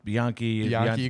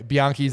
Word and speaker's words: Bianchi: Bianchi, you got it right.